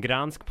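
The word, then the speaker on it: Gdańsk: Gdansk, Poland. – I think he pronounced that right. – sounded right.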